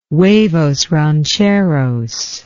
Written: huevos rancheros

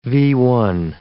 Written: V-1

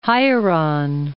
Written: Hieron

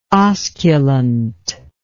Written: osculant

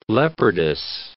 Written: leopardess

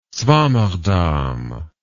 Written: swammerdam

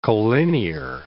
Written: colinear

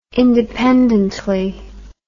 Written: independently

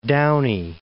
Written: Downey

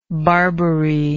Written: barbary

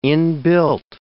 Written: inbuilt

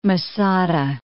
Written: Masada

